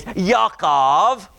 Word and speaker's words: Yaakov: Yaakov.